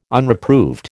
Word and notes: Unreproved: American English

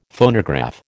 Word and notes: Phonograph: American English

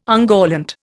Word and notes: ungallant: Indian

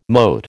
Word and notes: mote: American English

